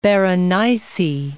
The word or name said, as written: Berenice